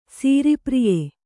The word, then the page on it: ಸೀರಿಪ್ರಿಯೆ: sīri priye